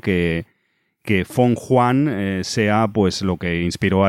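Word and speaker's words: Fenghuang: Se escribe Feng Huan, lo veréis en la de esta, pero estoy viendo que se pronuncia algo parecido a Feng Huan, que es como trataré de pronunciarlo durante todo este podcast.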